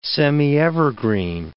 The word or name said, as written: semievergreen